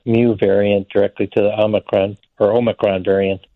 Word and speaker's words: Omicron: I've been corrected several times to say Omicron by my Greek colleagues. – So which is it, Omicron? Is that the proper pronunciation? – I think so, Omicron. – Omicron, all right.